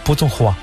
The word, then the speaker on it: Putonghua: El Putonghua, que es el mandarín, y nada más. – ¿Cómo se dice Putong? – Putonghua.